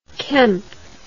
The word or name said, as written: Kempe